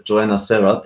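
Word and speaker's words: Joana Serrat: I saw her in England at a festival, and she was being introduced as Joanna Serrat, so I'll leave it like that.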